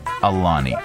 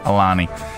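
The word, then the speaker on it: Ailany: Alani. – Okay, thank you. it's very a matter of fact. Alani.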